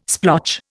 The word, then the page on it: splotch: Indian